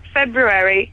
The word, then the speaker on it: February: Also, February does have an R in it. – Wow, Ella, you can come again. You're a fantastic caller. – Well, according to the Oxford English Dictionary, it says it has an R in it in the way that you pronounce it. – February. – Yes, yeah. February. No, February is spelt with an R following Feb, pronounced... Speakers insist that the R should be pronounced, but Feb-U rather than Feb-Ru is now becoming an accepted standard.